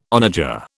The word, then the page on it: onager: British English